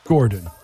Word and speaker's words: Gordon: Tonight, I'm chilling out, but you can follow me always at Michaela Gordon, M-I-K-A-L-A-H. – Gordon. – Gordon. G-O-R-D-O-N.